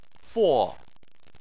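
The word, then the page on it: fo: tone 4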